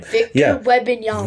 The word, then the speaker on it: Wembanyama: Wembanyama. – That's it. I can't pronounce his name. – Wembanyama.